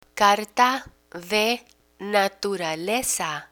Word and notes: carta de naturaleza: spanish

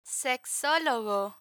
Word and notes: sexologo: spanish

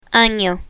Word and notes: ano: spanish

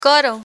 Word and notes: coro: Spanish

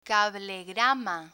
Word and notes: cablegrama: spanish

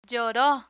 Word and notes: lloro: Spanish

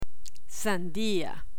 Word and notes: sandia: spanish

